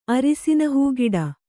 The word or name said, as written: ಅರಿಸಿನಹೂಗಿಡ